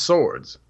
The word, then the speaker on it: Sowards: So it's actually pronounced swords. – Oh, are you serious? – Yeah. So like two swords.